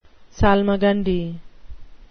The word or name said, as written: salmagundi